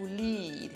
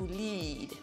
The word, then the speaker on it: lead: The first one, the one I have just told you about, is to lead, okay? It's a long E, to lead, led, led. And this one is lead, it's a short E. And the spelling is L-I-D, a lead.